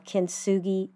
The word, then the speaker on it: Kintsugi: I want to encourage you to do a search on images of Kintsugi pottery. Okay, let me spell it for you. K-I-N-T, Kintsugi, S-U-G-I, Kintsugi pottery.